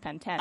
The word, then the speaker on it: Pantene: Apparently in the UK it's pronounced Pantene.